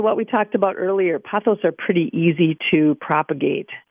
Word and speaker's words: pothos: This listener sent a text that said, I was recently in Florida and brought back, is it pronounced pothos? Pothos? – Pothos? Pothos vine, yep.